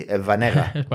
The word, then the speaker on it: Schwarzenegger: Bueno, pues el tema es que se pronuncia así, Schwarzenegger.